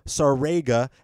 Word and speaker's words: Sarega: And his name is Andrew Sarega. And I only said it right because I said, like, Sega, right? – Yes.